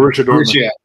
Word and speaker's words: Borussia Dortmund: Um, but like uh, uh, Bor- Boris Dortmund, how do you say it? BBB in uh, Germany. – Borussia Dortmund.